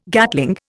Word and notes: Gatling: Indian

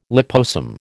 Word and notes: liposome: American English

